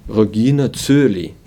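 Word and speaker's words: Regina Caeli: Ja jūs dzirdat šo, šo dziedājumu Vācijā, iespējams, ka, ka dzirdēsiet kaut kā, kā Rogīna Cūlī, kas man šķiet diezgan dīvaini, bet tāds ir tas vietējais akcents.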